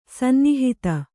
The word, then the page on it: ಸನ್ನಿಹಿತ: sannihita